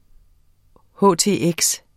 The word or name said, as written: htx